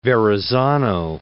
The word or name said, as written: Verrazzano